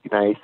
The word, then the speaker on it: gneiss: Gneiss? – Gneiss, yes. In Saint-Jean-Musol, it's more gneiss, so it's like an old granite, very sandy, very poor. – And what characteristics does the granite and the gneiss, you know, give to the wines?